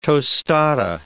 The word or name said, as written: tostada